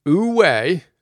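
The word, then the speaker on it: Wu Wei (无为): Wu Wei, again, W-U-W-E-I, pronounced U, kind of like U, Wei, and Da, spelled D-E, which is one of the coolest words ever, that is, unfortunately, pronounced Da.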